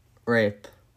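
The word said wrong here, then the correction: Ryp: Hey! Nope, it's not pronounced that. – I don't know how to pronounce that. Rip? – Reap. – Reap? Reap. Juan Jose Reap.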